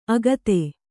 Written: ಆಗತೆ

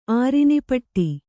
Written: ಆರಿನಿಪಟ್ಟಿ

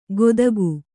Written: ಗೊದಗು

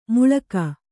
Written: ಮುಳುಗು

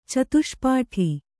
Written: ಚತುಷ್ಪಾಠಿ

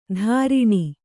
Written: ತಾರಿಣಿ

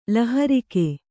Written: ಲಹರಿಕೆ

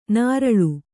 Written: ನರಳು

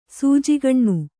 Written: ಸೂಜಿಗಣ್ಣು

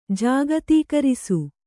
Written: ಜಾಗತೀಕರಿಸು

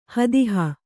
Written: ಹದಹು

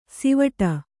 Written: ಸಿವಟ

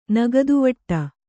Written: ನಗದುವಟ್ಟ

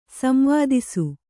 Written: ಸಂವೇದಿಸು

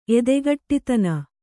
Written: ಎದೆಗಟ್ಟಿತನ

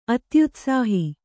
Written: ಅತ್ಯುತ್ಸಾಹ

